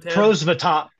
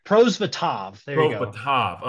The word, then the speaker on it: Prosvetov: Prosvetov? – Prosvetov. – Prosvetov.